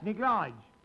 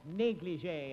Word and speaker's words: negligé: Standing on the balcony in her neglige. – In her what? – Neglige. Don't you speak French? – Don't be silly. That's pronounced negligé in France. – Well, it was pronounced neglige at our school.